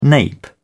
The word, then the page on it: nape: British English